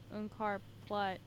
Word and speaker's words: Unkar Plutt: Un- Unkar Plutt. Yeah, that's how you say it.